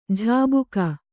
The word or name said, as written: ಝಾಬುಕ